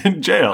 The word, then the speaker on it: gaol: It's spelled G-A-O-L. – There's no J's in the book. – Wait, how did the audiobook pronounce it? – Jail. – Oh, they just... Jail.